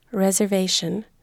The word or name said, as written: reservation